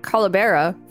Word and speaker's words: calavera: A calavera, pronounced Calabera for skull in the context of Day of the Dead, is a representation of a human skull or skeleton.